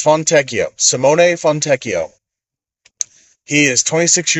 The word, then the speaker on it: Fontecchio: So this is how you say his name. You'll hear people say all kinds of ridiculous things. Okay, his name is Simone Fontecchio.